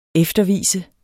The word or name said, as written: eftervise